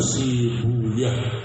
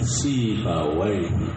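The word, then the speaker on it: سيبويه: Kalau dibaca si buyah, si buyah, ya haknya disukun, ya. Nah, sebagian mengatakan sama, mabni juga, cuma mabninya disukun si buyah. Baik. Dan sebagian orang suka bacanya si buyah, ya. Sinsua ya tapi yang masyhur pakai wain, si bawain.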